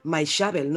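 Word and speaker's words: Maixabel: Maishabel. – Ah, ya está en Movistar Plus. Ah, sí, yo decía como tú, Maishabel, y ayer en la gala, Maishabel, no sabía que se pronunciaba así.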